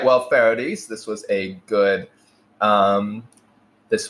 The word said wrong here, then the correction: Fardis: It's Fardis. That's what it is. And I know I've been saying it wrong, so I'm saying it on the air now. It's Fardis, and she told me this yesterday.